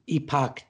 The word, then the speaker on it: Epoch: One you didn't mention is the Epoch Times. It's spelled E-P-O-C-H, but Epoch, it helps people understand the spelling.